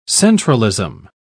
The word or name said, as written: centralism